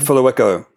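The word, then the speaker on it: Phehlukwayo: Fuluweko.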